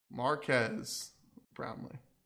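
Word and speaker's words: Marques Brownlee: Marcus Brownlee. – Say his name right. – How do you say his name? – Marquez Brownlee. – Oh, sorry. Marquez Brownlee.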